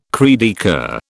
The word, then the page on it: cri de coeur: British English